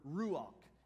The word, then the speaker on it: ruach: It's just not, you know, you got to have like that phlegm in your throat thing to really say it right. I'm not even going to try, all right? It's the word ruach.